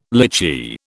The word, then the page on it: lychee: British English